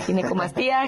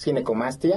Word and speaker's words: ginecomastia: Que es una, para empezar la palabra, le decía, ¿cómo se pronuncia? Ginecomastía, ginecomastia. – Sí, exacto. Es ginecomastia. Exactamente.